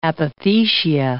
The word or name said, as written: apothecia